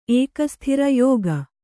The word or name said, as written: ಏಕಸ್ಥಿರ ಯೋಗ